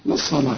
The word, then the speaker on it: للصلاة: أولا الصلاة دي تكتب بالواو وتنطق بالألف زي ما انت شايف كده، ورسم لك فوق الواو ألف عشان تحول الواو لإيه؟ إلى ألف، تقول للصلاة، لكن فخم لي الصاد ورقق لي اللام للصلاة من يوم الجمعة، من نون وبعدها ياء، والياء من حروف الإدغام هنا في غنة بقى، واخد بالك؟